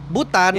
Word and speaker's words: Bhutan: BHUTAN. Hah? Butan kan? Heeh. Uh -uh. Iya BHU. Bacanya Butan. Iya, iya bener.